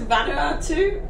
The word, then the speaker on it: Vanuatu: Vanuatu? Where is Vanuatu? What? How do you say that? – Vanuatu. – Where is Vanuatu? – That? – Vanuatu. Don't correct me.